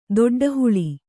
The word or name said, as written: ದೊಡ್ಡಹುಳಿ